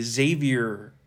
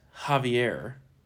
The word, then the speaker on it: Javier: I like to say Xavier Becerra. – Yeah. – But apparently it's Javier.